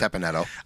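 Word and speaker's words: Teppan Edo: And number two is Teppanetto or Teppanito, whichever way you pronounce it. – I think it's it. – Teppanetto. – I do too, and that's I'll go with that. But I've on YouTube I've heard it both ways, so you can go either way.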